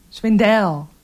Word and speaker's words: Swindell: Is het Swindell of is het Swindle? Hoe leggen we de klemtoon? – Swindell. Doe maar Swindell. – Swindell? – We doen Swindell.